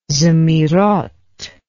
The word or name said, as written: zemiroth